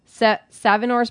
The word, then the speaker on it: Savinor's: He worked at restaurants like Flower Bakery, Savinor's Butcher. Did I say that right? – Yeah, that's right.